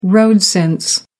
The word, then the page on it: road-sense: American English